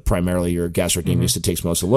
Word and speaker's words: gastrocnemius: So like so your gastrocnemius. Am I pronouncing that right? – Yep, yeah, you got it.